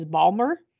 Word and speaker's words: Baltimore: I love how he pronounces Baltimore. He doesn't say Baltimore, he says Balmer. He's like outside Balmer.